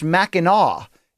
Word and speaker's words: Mackinac: He says, during fan subs of episode 351, Brian said Mackinac Island, and he's dead wrong. The island and the city are both pronounced Mackinac, even though the island is spelled differently.